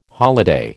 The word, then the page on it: holiday: American English